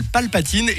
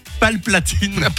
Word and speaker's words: Palpatine: Luciole qui nous dit « Bonjour, moi le mot que je prononce mal, enfin plutôt le nom, c'est l'empereur dans Star Wars. Il s'appelle Palpatine et je dis Palplatine. » Palplatine.